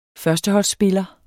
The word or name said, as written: førsteholdsspiller